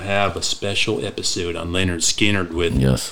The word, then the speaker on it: Lynyrd Skynyrd: I've got kind of the self-titled album, pronounced Lynyrd Skynyrd.